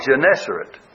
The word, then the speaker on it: Gennesaret: G-E-N-N-E-S-A-R-E-T. Gennesaret, I guess, is the way you pronounce it.